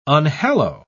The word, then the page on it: unhallow: British English